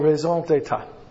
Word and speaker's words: raison d'état: That's how you pronounce it properly. In English you'd say it raison d'état. Which means reasons, d'état means a state. État is state. Here, let me show you something. Here, let me give you an education. Here, look. This is from 1 year. That's right. This is a French word. Okay? You never pronounce the last letter in the French word.